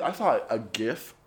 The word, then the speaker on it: GIF: the creator came out and was like, "It's pronounced GIF," and I'm like, "Like the peanut butter."